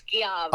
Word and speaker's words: Schiavo: It's Julie Skolnik, same spelling, Skolnik, hyphen, Schiavo, Schiavo. And for all the Italians out there, I know it's actually pronounced Schiavo.